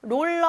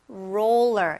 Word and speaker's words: roller: 처음에 R로 시작하니까 roller 아니고 roller 이렇게 R 발음을 해주세요.